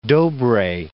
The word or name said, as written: Dobree